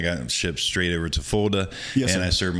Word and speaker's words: Fulda: Am I saying that correctly? – Fulda, Germany.